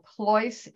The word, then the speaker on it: Pleuss: So Ploise is spelled P as in Peter, L-E-U-S-S as in Sam, Sam.